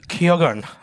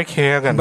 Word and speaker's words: Keoghan: Keoghan. Barry Keoghan.